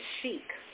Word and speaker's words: sheikh: sheikh. I didn't even know what a sheikh was. – Mm. – Yeah. Am I pronouncing it right? – You could say sheikh.